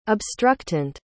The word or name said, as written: obstructant